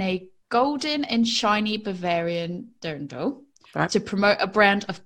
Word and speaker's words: dirndl: The dirndl? – Dirndl.